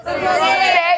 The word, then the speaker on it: rosé: No, you're supposed to say... How do you say it? – Rosé. – It's like Jose.